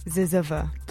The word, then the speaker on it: zyzzyva: It's spelled Z-Y-Z-Z-Y-V-A. Ziziva.